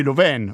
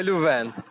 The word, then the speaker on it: Louvain: Aiutami a pronunciare la tua università, Université Catholique de Louvain. Ho pronunciato bene? – De Louvain, perfetto.